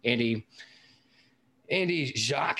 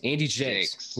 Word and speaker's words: Jacques: Andy Jacques. How do you say his name? You said it last time. Andy Jakes. We call him Jacques because he's Frenchy. But Andy Jakes.